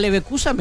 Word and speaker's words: Leverkusen: Al Bayer Leverkusen, me encanta. – Lo dijiste muy bien. – Al Bayern Múnich, vos preferís ver a todos esos. – Al Bayern München y el Bayer Leverkusen.